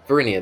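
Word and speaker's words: Varinia: Verinia, there you go.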